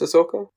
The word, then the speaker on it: Ahsoka: Is it pronounced Ahsoka? – Yeah.